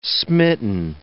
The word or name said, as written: smitten